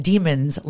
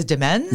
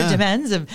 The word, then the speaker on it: Demens: And it's interesting because we call it Demons Landing. – But the a correct pronunciation apparently is Demens. – Really? – And, yeah, okay. Peter Demens.